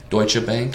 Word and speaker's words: Deutsche Bank: And then like Deutsche Bank or whatever. Mm-hmm. Is that how you pronounce it? – Deutsche Bank.